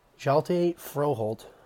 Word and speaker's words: Hjalte Froholdt: It's actually pronounced Hjalte Froholdt. Sorry, my spelling is terrible. He spells it H-J-A-L-T-E, so definitely a difficult name there.